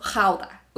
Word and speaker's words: Gouda: Una es cómo se pronuncia el queso Gouda, creo que se pronuncia en español, eh, es G-O-U-D-A. En holandés, que pues la palabra es de allá, es queso jauda, con esta como, ¿no?